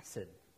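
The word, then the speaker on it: chesed (חסד): To say it properly, you kind of have to get a little guttural thing going, chesed.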